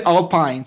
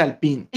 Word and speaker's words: Alpine: No se dice Alpine, se dice Alpine.